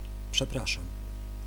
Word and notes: désolé: IPA: /de.zɔ.le/

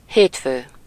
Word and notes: Monday: English

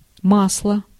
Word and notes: olie: [o.liː]

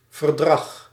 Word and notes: verdrag: /vǝɾ.dɾɑɣ/

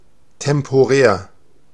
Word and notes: tijdelijk: /tɛj.də.lɛk/

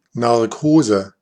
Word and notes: Narkose: /nar.ˈkoː.zə/

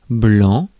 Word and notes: blanc: France; [blɑ̃]

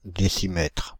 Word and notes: décimètre: Paris; [de.ɬ͡si.mɛtʁ]

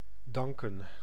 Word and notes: danken: /ˈdɑŋ.kə(n)/; Dutch

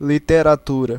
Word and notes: litteratur: [ˌlɪt.te.raˈtʉː r]

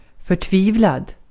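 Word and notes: förtvivlad: Swedish

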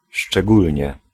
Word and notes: surtout: France; [syʁ.tu]

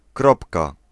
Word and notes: kropka: /ˈkrɔpka/

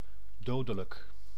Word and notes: mortel: France; [mɔʁ.tɛl]

